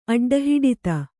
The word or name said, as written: ಅಡ್ಡಹಿಡಿತ